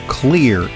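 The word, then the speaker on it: Xlear: Nathan is the CEO and founder of Clear, Inc.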